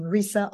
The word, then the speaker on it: Risa: And it's spelled M-I-C-H-E-L-E, Michele with one L, last name Risa, R-I-S-A.